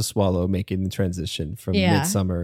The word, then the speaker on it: Midsommar: The reason we pronounce it Midsommar is that's how Ari Aster pronounces it. – Yeah. – So not a pretentious thing, just a, that is how the director of the film pronounces the title of the film.